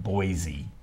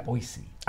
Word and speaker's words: Boise: Boise, and we always had to get it correct. Not Boise. – Yeah. – Boise. – I've always pronounced it Boise, but now I've learned something. I will pronounce it correctly from here on out.